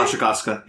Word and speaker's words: Wasikowska: Is that how you pronounce her name? – Wasikowska, yeah.